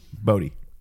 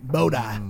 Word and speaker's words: Bodhi: What's your dog's name? Bahodi? – Bodhi. – Bahodi? – How do you spell it? – Bodhi. He's named after Patrick Swayze's character in Point Break. – Bodhi.